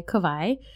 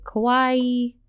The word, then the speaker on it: Kavai: And I go by Kavai. And a lot of people can't pronounce my name, especially up here in the mainland. They all pronounce it wrong. They're like Kawaii, Kawaii, Kawaii. I had many people just pronounce my name horribly wrong up here.